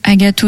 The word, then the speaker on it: Agathe: Il suffit de taper Agathe Odell. – Comment ça s'écrit? – Alors, Agathe, comme le prénom. A-G-A-T-H-E.